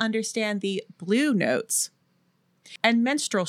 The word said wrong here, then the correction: minstrel: shows, is that how you say it? Or am I saying it like a minstrel. show on their period? No, I mean, that is also how you pronounce menstrual, but it's minstrel, yeah.